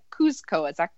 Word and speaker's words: Kuzco: All right, so I have Rick Forbes, and he said Kuzco.